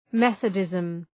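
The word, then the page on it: Methodism: ˈmeɵəˌdızm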